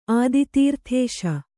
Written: ಆದಿತೀರ್ಥೇಶ

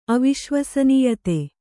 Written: ಅವಿಶ್ವಸನೀಯತೆ

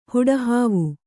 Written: ಹುಡಹಾವು

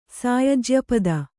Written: ಸಾಯಜ್ಯಪದ

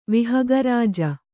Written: ವಿಹಗರಾಜ

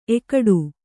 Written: ಏಕಟ

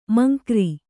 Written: ಮಂಕ್ರಿ

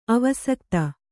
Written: ಅವಸಕ್ತ

